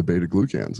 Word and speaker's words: beta glucans: You're getting a fraction of the beta glucans that you can.